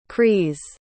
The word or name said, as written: crees